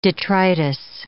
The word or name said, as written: detritus